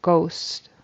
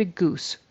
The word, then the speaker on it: goose: And then goose, it sounded like ghost. I almost thought it was a ghost. A ghost was waiting for her.